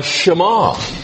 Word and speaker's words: Shema: In fact, they nicknamed it the Shema. And I don't know whether there are accents in Hebrew, but I'm going to put an accent there because the emphasis is on the second syllable.